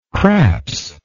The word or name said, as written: craps